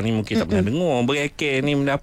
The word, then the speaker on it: bereke: Tapi perkataan kita malam ni ejaannya adalah B-E-R-E-K-E. Bunyi dia adalah bereke.